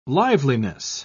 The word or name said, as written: liveliness